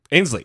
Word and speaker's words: Ainsley: L- like the Aisley Earhardt interview. Did I say her name right? – Ainsley.